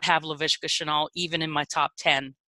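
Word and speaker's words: Laviska Shenault: Then you got Jonathan Taylor, Justin Ross, and LaVisca Chenault, if I said his name correctly.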